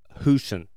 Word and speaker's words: Husen: Dr. Stephanie Hewson, H-U-S-E-N, Hewson. She was Houston. I actually did the pronunciation out to the side of it. Houston. She was described as, quote, a brilliant sports medicine doctor.